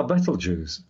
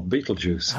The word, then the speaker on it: Betelgeuse: Betelgeuse, or Betelgeuse, you want to pronounce it.